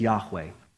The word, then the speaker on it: Yahweh: YHWH, we oftentimes will pronounce it as Yahweh.